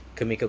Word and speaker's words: Kimiko: Kimiko Glenn. – How do you spell it? – K-I-M-I-K-O Glenn, G-L-E-N-N. – Chameleon go slower. Glenn. – So it's like Kimmy, like K-I-M-I, and then K-O. – No, is it the K? – Yeah. – K-I-M. – I-K-O. – I-K-O.